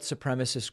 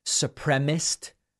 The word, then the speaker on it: supremacist: By the way, I hear sometimes supremacist pronounced supremist. Like I think Lindsey Graham says it that way and some others. I don't know where they're getting supremacist. Supremacist is the word.